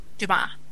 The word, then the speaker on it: Dumas: Dumas.